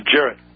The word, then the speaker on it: Jarrett: It's Jarrett. It's a soft G. Jarrett Verschuer, yeah.